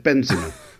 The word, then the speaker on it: Benzema: He pronounced it like marzipan, but Benzema.